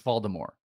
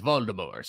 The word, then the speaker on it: Voldemort: I actually think it's a silent T. I think it's Voldemort. They don't say Voldemort. Say Voldemort. – They do. – No, they don't. You know what? – Okay. All right. You know what? You would know better than me. – I've watched. – I've always thought there was a T on the end of that. – No. I think you're right, but they don't pronounce it. It's Voldemort. They say it's Voldemort.